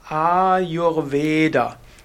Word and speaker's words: Ayurveda: A ist lang und E ist lang, auch lang und U ist kurz und das Da ist lang. Also vom Sanskrit her würde man sagen Ayurveda, Ayurveda, Ayurveda.